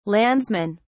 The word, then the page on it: landsmen: British English